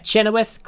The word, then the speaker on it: Chenoweth: Yeah, Chenoweth.